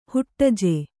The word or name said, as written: ಹುಟ್ಟಜೆ